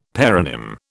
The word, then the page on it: paronym: British English